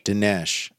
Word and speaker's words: Dinesh: Denise D'Souza? How do you say his name? – Dinesh.